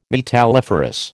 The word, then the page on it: metalliferous: American English